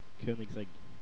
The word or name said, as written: Koenigsegg